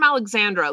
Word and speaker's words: Alexandra: When I travel, if people say my name is Alejandra or my name is Alessandra, I'll roll with it. But in the end, like, I was like, no, I'm Alexandra.